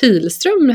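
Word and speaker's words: Pihlström: mitt flicknamn var ju faktiskt Pilström, hette jag en gång i tiden. P-I-H-L-ström.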